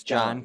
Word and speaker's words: John: Yeah, the H, you pronounce the H very softly. My name's John Kokoris.